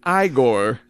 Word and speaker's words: Igor: It's pronounced Igor.